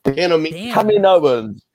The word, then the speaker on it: Kaminoans: Kaminoans.